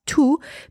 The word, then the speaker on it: tous: I am pronouncing T-O-U-S as tout because there is a noun after tout.